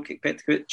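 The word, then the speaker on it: Petković: Is it Petkovic? Is that how you say it? Bruno Petkovic looked much better. – Yeah, that's how you say it, yeah.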